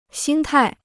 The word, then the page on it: 心态: xīn tài